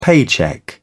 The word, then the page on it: paycheck: British English